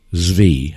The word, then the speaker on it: Zvi: And it's spelled exactly like it sounds, Sabbatai, but his last name is it's Z-V-I, Zvi, however you want to say it, okay? – Zvi.